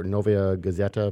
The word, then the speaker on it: Novaya Gazeta: But uh, this the the Russian uh, paper newspaper Novaya Gazeta. Am I pronouncing that at all correctly? Mm-hmm. That is, yeah, that's correct.